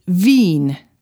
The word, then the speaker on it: Wien: The Austrian capital, Vienna, is Wien.